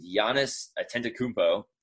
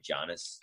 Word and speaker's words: Giannis Antetokounmpo: Most recently, Giannis Antetokounmpo. I think I said that correctly. Giannis. – I think so. Yeah.